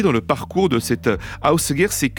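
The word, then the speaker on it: Ásgeir: Le nom s'appelle ça s'écrit Asgeir, A-S-G-E-I-R, mais je crois que d'après ce que j'ai compris, on prononce à peu près Ausgeir, voilà. Il s'est fait connaître très jeune, cet Ausgeir,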